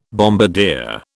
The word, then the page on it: bombardier: British English